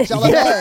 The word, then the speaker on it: Chalamet: That's how you say it. Chalamet.